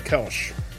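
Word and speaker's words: Kölsch: Well, I wouldn't call it a Kolsch. I would call it a Kelsch. – Why would you call it a Kolsch? – Because you got to pronounce the umlaut over the O.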